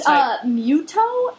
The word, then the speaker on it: Mytho: It's Muto is the other guy. It's spelled Mytho, and I wish it was pronounced Muto. – Muto. I wish it was pronounced Mytho because Muto sounds like Mewtwo.